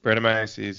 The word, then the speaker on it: Brettanomyces: With, and you're going to have to help me with the pronunciation here, Brettanomyces? – Brettanomyces, yeah. – Brettanomyces. – Dude, you had it. You just said it slowly.